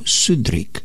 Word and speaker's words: Southwark: Southwark se tehdy vyslovoval Sudrick. Dnes se vyslovuje Southwark, či tak nějak.